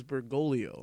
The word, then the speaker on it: Bergoglio: No, but it's spelled Bergoglio, but the pronunciation I'm under it says Bergoglio. – Yeah, it's Bergoglio. – What happened to the other G? – It's fucking Italian, man.